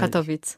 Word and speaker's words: Katowice: Weil Katowice. – Katowice. Guck mal, ich hätte jetzt Katowice gesagt. – Also in Deutschland sagt man Katowice. – Ja. – Und auf Polnisch ist es Katowice.